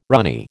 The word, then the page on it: runny: American English